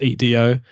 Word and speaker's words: Edo: And then the last one was Twilight of Edo Japan. I don't know if I'm saying that right again. That's Edo.